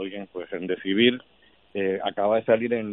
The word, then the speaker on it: Remdesivir: que todavía Trump no ha aprendido a pronunciarlo, estaba diciendo el otro día Rendecibois como si fuera una droga francesa.